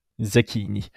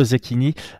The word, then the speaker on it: Zecchini: Par contre, le nom de famille, je t'en prie. Zecchini. Zekini. Ok, donc Manolo Zekini signe à l'UFC pour affronter Morgan Charrière.